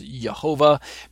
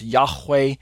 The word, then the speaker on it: Yahweh: But then this next word actually is the tetragrammaton name of God, many times pronounced Yehovah, many times pronounced Yahweh, Jehovah, Yehovah, Yahweh, Yahweh, Yehoah, various types of pronunciations. I'm of the inclination that Yahweh is the more accurate of those pronunciations.